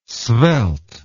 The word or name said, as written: svelte